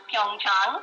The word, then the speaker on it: Pyeongchang: It is pronounced Pyeongchang, and that's a county, a kind of rural area in Korea, about 130 kilometers from Seoul.